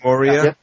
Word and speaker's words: Moria: The mines of Moria. That's a thing, right? Did I say that correctly? Did I say that correctly? Moria? – Yep.